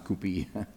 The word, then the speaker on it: coupe: It's a coupe, but it's pronounced coupe. It looks spelled coupe, but it's coupe, C-O-U-P. So that's the way a lot of languages are. There's a letter there, but it's silent.